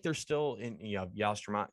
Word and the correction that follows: Yastrzemski: I get Yastrzemski. – that. – Yastrzemski.